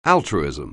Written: altruism

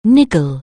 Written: niggle

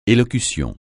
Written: élocution

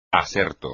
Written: aserto

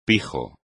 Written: pijo

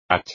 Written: hacha

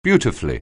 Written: beautifully

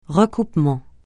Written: recoupement

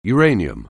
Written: uranium

